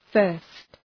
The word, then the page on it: first: fɜ:rst